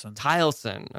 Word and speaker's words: Taliesin: So in 1911, you decide to build a house in Wisconsin called, I want to make sure I'm pronouncing this right, Taliesin, is that right? – Taliesin.